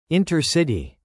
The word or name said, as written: intercity